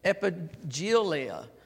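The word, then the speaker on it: epaggelia: Now, that word promise right there, I'm going to spell it for you. It's a E-P-A-G-G-E-L-I-A, epigelia.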